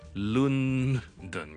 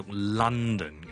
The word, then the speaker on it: London: 有个很重要的就是伦敦我们是不会读是 London 的， OK，我们读 London 的 London 第一个音节呢听下去有点像 lunch 那个 lunch 那个 lun 的， OK，所以我们读 London。